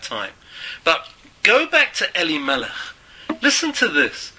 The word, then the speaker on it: Elimelech: What does the name Elimelech, how does it break down in syllables? Two syllables. The first syllable, Eli, or Eli, to me. And the second syllable, or second word, Melech, king. To me is the king. Eli, Melech.